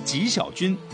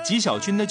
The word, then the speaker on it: 纪: 台湾还有一位原住民歌手很出名的女歌手叫纪晓君，纪呢就是纪律的纪嘛，读成姓的时候念纪，纪晓君的舅舅。